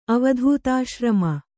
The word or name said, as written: ಅವಧೂತಾಶ್ರಮ